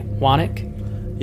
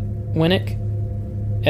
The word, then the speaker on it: Winek: W-I-N-E-K, Winick? – Yeah, Winick or Winick. – Winick or Winick. – Winick. Winick. – Winick. Mark Winick, who was 69, his wife, Sherry Winick, S-H-A-R-I-E, Sherry? – That's it, yeah.